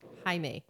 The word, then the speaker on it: Jaime: Yeah, well, you know, Jamie, you spell your name Jamie, but you pronounce it Jaime? – Jaime. – Yeah, Jaime. So the J's like an H.